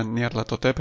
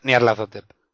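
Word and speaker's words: Nyarlathotep: De hecho, Nayar Latotep, yo creo que casi todo el mundo lo pronuncia Nayar Latotep en castellano, ¿no? – Sí, yo lo pronuncio así, Nayar Latotep.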